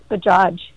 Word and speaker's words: Bajaj: Yes, and both the J's are hard J's, so it's just pronounced just as it looks, Bajaj.